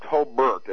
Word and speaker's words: Hobart: It is pronounced Hobart, as it were an E in there.